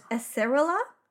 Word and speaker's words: acerola: Acerora, Acerora. – We can never say this right. Ace- Acerola. I can, yeah, I can't say it.